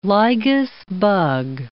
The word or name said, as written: lygus bug